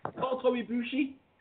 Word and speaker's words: Kota Ibushi: I can think of one guy that if they decide to keep him on, and that's Kota Ibushi.